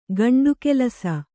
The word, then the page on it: ಗಂಡುಕೆಲಸ: gaṇḍu kelasa